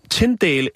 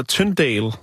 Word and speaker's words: Tyndale: William Tyn, Tyndale, eller Tyndale, øh, alt efter hvordan man vil udtale det, øh, for det kan faktisk udtales både med i og med y.